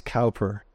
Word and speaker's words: Cowper: Think of William Cowper, sometimes pronounced Cowper, and we'll sing one of his hymns later on today.